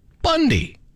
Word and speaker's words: Bounde: The last name is spelt B-O-U-N-D-E. And it's pronounced Bundy.